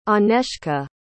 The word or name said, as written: anezka